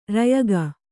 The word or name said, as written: ರಯಗ